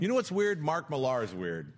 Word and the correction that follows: Mark Millar: Mark Miller, sorry, I always say it wrong because of the way it's spelled, but it's Mark Miller.